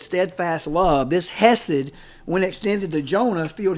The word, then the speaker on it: hesed: The word is spelled H-E-S-E-D, hesed.